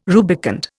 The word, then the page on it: rubicund: Indian